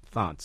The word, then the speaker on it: Thant: It's pronounced Thant, so thank you.